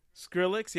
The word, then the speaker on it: Skrillex: Yeah, you definitely do not want to play something like a Skillet. How do you say his name? Skrillex? – Skrillex? – Skrillex, yeah. You